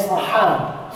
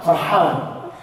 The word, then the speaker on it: Farhan: So my name is not Fir'aun, my name is Farhan. Farhan. Yeah, it means happy.